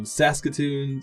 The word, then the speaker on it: Saskatoon: But we have someone I want to shout out to in Saskatoon, Saskatchewan.